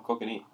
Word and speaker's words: kokanee: No, kokanee.